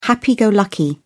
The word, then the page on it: happy-go-lucky: British English